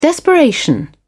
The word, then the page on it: desperation: British English